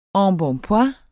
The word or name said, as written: embonpoint